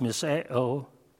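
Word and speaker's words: misao (μισέω): The Greek word here is spelled mu, iota, sigma, epsilon, omega. It's pronounced misao, and it can mean hatred in a certain context.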